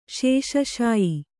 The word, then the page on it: ಶೇಷಶಾಯಿ: Kannada